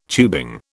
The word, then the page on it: tubing: British English